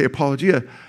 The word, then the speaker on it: apologia: Just say the word apology with me. Apology. Apology. I'll just add a at the end. Apologia.